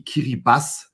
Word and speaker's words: Kiribati: Das Land heißt tatsächlich Ki Kiribati TI gibt es im Kiribatischen nicht, äh, sondern oh. TI wird als S ausgesprochen.